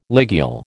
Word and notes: ligule: American English

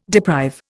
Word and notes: deprive: Indian

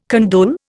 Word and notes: condole: Indian

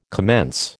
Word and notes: commence: American English

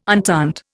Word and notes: entente: Indian

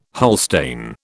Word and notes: Holstein: British English

